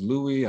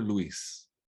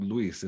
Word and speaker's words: Luis: I was Louis, I was Louis, but I was never Luis. And if I insisted on being called Luis, if I reminded people that my name is Luis, I'm hard to work with.